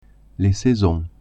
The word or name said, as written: saisons